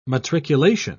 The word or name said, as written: matriculation